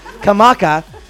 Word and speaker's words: Kamaka: Did I say that right? I didn't say it right, did I? I murdered it. I did it wrong. Kamaka.